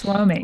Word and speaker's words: WOMI: And it made me realize that I'm not sure how you envision it being pronounced, but I pronounce it when I read it, WOMI. – Yes, WOMI. Right? Correct. You got it.